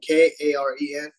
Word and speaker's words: Karen: We're a ethnic group called the Karen, like Karen. It's spelled K A R E N. It's pronounced Karen, but you know, it's not the Karen that you know.